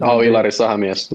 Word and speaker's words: Ilari Sahamies: Ilari Sahamies.